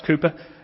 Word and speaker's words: Cowper: And one of the chapters was on William Cowper, pronounced Cooper, spelt Cowper, the great 18th century poet, a Christian.